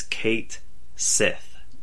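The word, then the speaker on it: Cait Sith: And Square Enix felt the need to put out a notice to everyone that this character is going to be pronounced in game, and this is how players should pronounce it, as Kate Sith.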